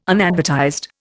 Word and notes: unadvertised: Indian

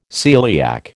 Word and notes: celiac: American English

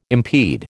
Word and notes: impede: American English

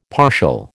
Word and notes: partial: American English

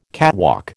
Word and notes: catwalk: American English

